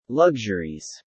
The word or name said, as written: luxuries